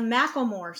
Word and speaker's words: Macklemore: And she's the one in the Macklemore song.